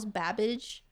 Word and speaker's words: Babbage: Did you think Charles Babbage? – I don't even know. How do you spell that? – B-A-B-B-A-G. – So it's literally Cabbage except with a B.